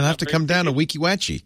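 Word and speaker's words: Weeki Wachee: Is that right? Am I saying it right? Wekiwatchee? – You certainly are, yes.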